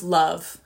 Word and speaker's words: LOVB: So finally, we have League One Volleyball, pronounced Love, L-O-V-B, but it's pronounced Love.